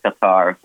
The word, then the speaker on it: Qatar: And do they pronounce it Qatar or Qatar? You hear both ways. – Yeah, no, we only heard Qatar when we were down there.